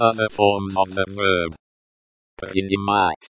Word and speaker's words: Принимать: Принимать. Принимать. The stress falls on the third syllable.